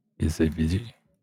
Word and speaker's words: BCBG: BCBG. You gotta pronounce it like European.